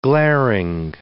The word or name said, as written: glaring